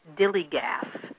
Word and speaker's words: DILLIGAF: And, I don't know if you've ever seen anybody wear this thing that says Dilly Gaff, which is spelled D-I-L-L-I-G-A-F.